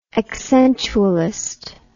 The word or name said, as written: accentualist